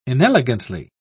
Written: inelegantly

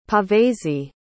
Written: Pavese

